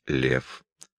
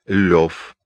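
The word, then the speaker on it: Лёв: Толстой образует фамилию своего героя, русского аристократа, прообразом которого служил молодой Толстой, от своего собственного имени Лев. Толстой произносил свое имя как Лев, а не Лев.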